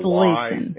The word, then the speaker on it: Slaton: It's Slaton, and it's not it's pronounced Slayton, like there's a Slayton. – Y, like there's a Y and an E.